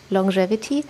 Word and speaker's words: Longevity: Also ich habe zu Longevity recherchiert, schreibt sich Longevity, aber man spricht tatsächlich Longevity.